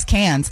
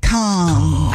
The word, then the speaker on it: Cannes: I always used to think it was Cannes, but it's Cannes. – I thought it was Cannes. – No, it's Cannes.